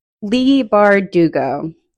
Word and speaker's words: Bardugo: Leigh Bardugo. That's how you say it.